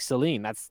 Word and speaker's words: Selene: See, and I didn't think that because it's spelled S-E-L-E-N-E, but sounds like Celine.